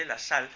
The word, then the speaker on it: Lassalle: es que tal como se escribe, Lassalle, y Carlos Marx.